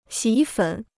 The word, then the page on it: 洗衣粉: xǐ yī fěn